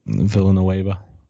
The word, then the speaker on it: Villanueva: Listen, they've been trying to replace holes with Pouncey, Villanueva, and all of them. I think I pronounced that right. – Villanueva.